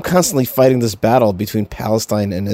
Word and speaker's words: Palestine: Now, don't try to correct me and tell me Palestine, because that's not the way Palestinians, that is, Palestinians from Palestine, Texas, pronounce that, the name of that town. They pronounce it Palestine.